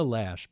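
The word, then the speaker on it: Loesch: And it's Dana Lash, by the way, if you really want the exact pronunciation.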